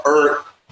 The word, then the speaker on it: Ert: Ert, pronounced like Ert from the area.